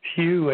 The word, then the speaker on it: Hugh: My name is Hugh, H-U-G-H. Last name Trolson is spelled T like Tom, R-A, U-L-S like Sam, E like Edward, N like Nancy.